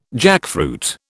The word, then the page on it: jackfruit: British English